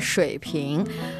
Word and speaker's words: 水平: Actually, this word Shui Ping shares the same pronunciation with water bottle.